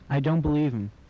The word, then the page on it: believe him: English